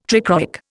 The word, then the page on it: trichroic: Indian